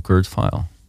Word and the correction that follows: Kurt Vile: Zeg je dat wel? Kurt File? Denk het wel. Ik heb dat nooit uitgesproken. Kurt Vile. Vet. – V-I-L-E. Ja.